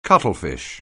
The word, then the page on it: cuttlefish: anglais